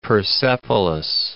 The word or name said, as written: Persepolis